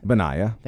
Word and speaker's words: Bagnaia: Bagnaia.